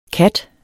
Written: kat

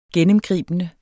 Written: GENNEMGRIBENDE

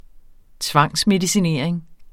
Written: tvangsmedicinering

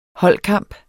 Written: holdkamp

